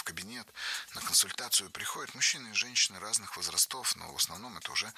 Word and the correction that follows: возрастов: Было сказано «возрастов», но это неверно. Ударение в нашем слове должно падать на первый слог «возрастов».